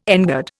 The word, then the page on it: engird: Indian